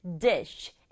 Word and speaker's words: dish: 발음은 dish. sh의 소리를 sh, 바람처럼 내서 한 박자 단어가 되는 거예요. dish 아니고 dish.